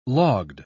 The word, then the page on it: logged: British English